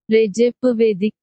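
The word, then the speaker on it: Recep İvedik: Well, and here's how you pronounce that. – Recep Ivedik 5.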